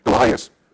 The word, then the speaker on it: Goliath: One is when he was facing Goliath? Did I pronounce it right? Yeah, okay, thank you. Goliath, that's a Shaq with David.